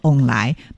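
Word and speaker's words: 旺來: 对，除此之外呢，其实啊，还有就是我们在台语当中的凤梨啊，其他的读音叫做翁来，那翁来呢，就是有好运旺旺来的意思。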